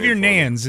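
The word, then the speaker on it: Neyens: Go ahead and pronounce Xavier's last name. – Xavier Nans is how Rob Manfred pronounced it. – I'll get it someday. – Xavier Nans is his name.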